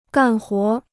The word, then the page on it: 干活儿: gàn huó r